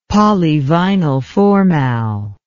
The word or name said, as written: polyvinylformal